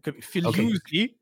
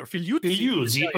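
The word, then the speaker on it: Figliuzzi: Well, I speak Italian. It depends how he pronounces it the Italian way or the American way. Fugliuzzi would be in it. – Fugliuzzi. Okay, so we'll pronounce it the Italian way and give some respect.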